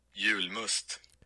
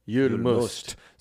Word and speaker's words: Julmust: This is how you say it in Sweden. Here we go. – Julmust. – Julmust. – So it's spelled J-U-L-M-U-S-T and Jul is for Yule.